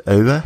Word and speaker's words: Euwe: So now I guess now many of the listeners also know how to pronounce it. It's Euwe.